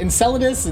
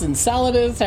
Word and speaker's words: Enceladus: Of like, is it Enceladus, Enceladus? – I've heard it pronounced Enceladus, but I've heard it also pronounced the other way too.